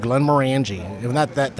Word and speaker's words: Glenmorangie: Okay, so today I'm going to do an ounce and a half of Glenmorangie. – Ounce and a half of Glenmorangie. That's the proper saying of it, too. – Is it? Do I say it correctly? – Yeah.